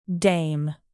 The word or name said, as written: dame